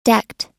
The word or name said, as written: decked